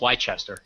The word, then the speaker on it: Leicester: a 7-year study at the University of Leicester. Do you guys know how to pronounce that? – I think it's Leicester.